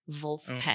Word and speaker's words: Vulfpeck: V-U-L-F-P-E-C-K. Wolfpack. Oh, came in right away. – Yes, that's right.